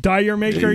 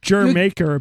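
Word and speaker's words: D'yer Mak'er: Dyer maker, yeah. You haven't pronounced that? Yeah. It's not that. I'll tell you that right away. – All right, this is enough. – It's pronounced Jer Maker, apparently. I never knew that.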